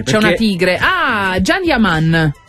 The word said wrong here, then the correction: Can Yaman: Can Yaman, ma si legge Gian.